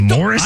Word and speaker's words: Maurice: M-A-U-R-I-C-E is spelled or pronounced Morris.